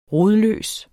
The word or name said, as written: rodløs